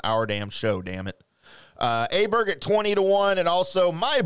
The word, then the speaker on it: Åberg: that is not how you pronounce his last name. It is not Åberg. It is Åberg.